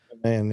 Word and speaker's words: Ghanaian: Ghanaian pilot? Is that how you pronounce Ghanaian. – It? Ghanaian pilot. – Ghanaian, yes.